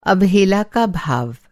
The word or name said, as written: अवहेला